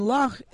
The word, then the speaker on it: Lough: E-R-N-E, Resort. And Loch, it means the same thing as, you know, Loch in Scotland, like Loch Lomond, but it's spelled L-O-U-G-H. Which is the Irish spelling for the word.